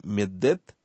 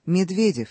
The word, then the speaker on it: Медведев: Медведева. Что-то в этом роде. Да. – Итак, то ли Медед, то ли Медедевар.